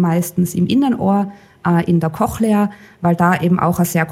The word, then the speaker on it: Cochlea: Wir sehen da Trommelfell, Gehörknöchelchen, Cochlea, habe ich es richtig ausgesprochen? – Ja, genau. Ja. Cochlea oder Hörschnecke kann man auf Deutsch auch sagen.